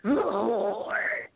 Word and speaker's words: Horde: You guys beat the Horde in Sabotage. What was that like? – First of all, you're not saying it right. It's Horde. You know, you gotta draw it out, right?